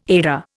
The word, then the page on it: Edda: Indian